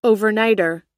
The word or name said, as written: overnighter